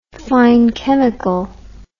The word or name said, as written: fine chemical